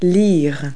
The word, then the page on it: read: verb